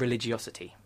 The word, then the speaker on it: religiosity: They picked parts of the 5-point personality ones, but they had three of them: income, social attitudes, and religiosity, which I think is the way you pronounce that word.